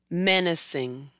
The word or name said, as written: Menacing